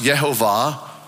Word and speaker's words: Yehovah: It consists of four Hebrew letters, YHWH, and it's pronounced Yehovah or Yahweh.